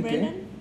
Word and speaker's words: Brennan: o cómo se dice su apellido? – Ian, Brennan. – Sí, ¿verdad? – Ah.